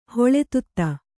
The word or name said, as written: ಹೊಳೆತುತ್ತ